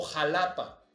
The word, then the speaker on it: Xalapa: Entonces, es por eso que ahora en México pasan cosas como la colonia Joco y se escribe Choco, o Jalapa y se escribe Xalapa, ¿no?